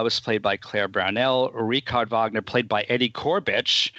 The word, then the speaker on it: Richard: So there were professional aspects to the production, but that didn't extend to the pronunciation, the correct pronunciation of the characters' names, because it's Richard Wagner, but people kept saying Richard, and it's Cosima Wagner, but people kept saying Cosima as if she were Italian.